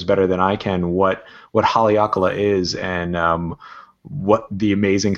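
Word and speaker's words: Haleakalā: And Haleakala, with a little emphasis in the last syllable.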